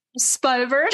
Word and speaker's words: Smibert: And next guest we have is John Smibert. Am I pronouncing that correct? Smibert.